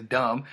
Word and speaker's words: dumb: Like the word dumb is spelt dumb. That's dumb. That's dumby. – Dumb. – Dumb is spelt dumb. Like, why put the B there? – I was watching What You Call on that.